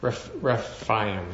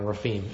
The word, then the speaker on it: Rephaim: Rephaim, and I'm saying that wrong, Rephaim.